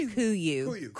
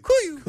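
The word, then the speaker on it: KUIU: It's pronounced Kuyu. – Kuyu. – Kuyu.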